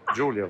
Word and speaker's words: Giulio: No, hai sbagliato. Giulio, forse. – Non è Gaulio Cesare? – No, no, Giulio. – Lo so, ma in America la I si legge AI, no? – Ah, quindi Gaulio? Dite Giulio Cesare? – Sì, qui in Italia.